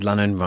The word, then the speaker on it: Lannan: My name is Richard Lannan, rhymes with Cannon, and this is the Fenestration News Podcast.